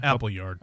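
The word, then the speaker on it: Appleyard: Despite decades of competition, there have been recent collaborations between Duncan McCall and Apply Yard Agency. – Apple Yard. – Okay, it's spelled wrong.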